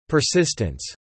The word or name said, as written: persistence